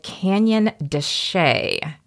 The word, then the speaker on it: Canyon de Chelly: and this is called Canyon de Chelly. – Yeah, it's spelled Chelly, but it's not pronounced that way.